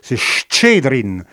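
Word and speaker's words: Chtchedrine: Ah, c'est compliqué à prononcer pour les Français parce que c'est Chtchedrin.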